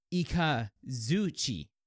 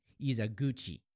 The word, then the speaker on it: Ikazuchi: And he says, all I can say is it's pronounced Ikazuchi. If I knew more about literally sound editing,